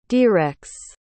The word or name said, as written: derex